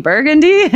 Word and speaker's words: Burgundy: and they were like, "Burgundy? You mean Burgundy?" And I was like, "No, here it's Burgundy."